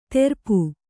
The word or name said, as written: ತೆರಪು